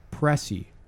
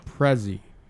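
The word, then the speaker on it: pressie: Also, I'm going to give you a different pronunciation of it. Prezi.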